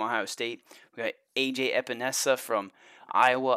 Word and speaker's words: Epenesa: I mean, those are two premier pass rushers there, and I may have butchered his name. I was saying Espinoza all freaking off season long, so at least I got close.